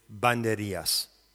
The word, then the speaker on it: Banderillas: The nonverbal component is the image of the drawing by Pablo Picasso, which is titled Dance with Banderillas. That is the correct pronunciation, by the way. Banderillas. Banderillas.